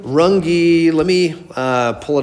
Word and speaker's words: Runge: Stephen Runge is how you say his name.